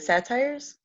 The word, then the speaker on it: satyrs: Um, a nymph was female, and excuse my pronunciation, but it's, it's spelled S A T Y R S.